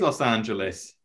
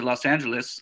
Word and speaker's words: Los Angeles: A lot of English people pronounce it Los Angeles, weirdly. – Yeah. – Los Angeles. – I would go with Los Angeles. – Yeah. Okay. – Which is correct? – Well, the American pronunciation would be Los Angeles. Did you catch the difference there? But the actual pronunciation really, I guess, would be like Los Angeles.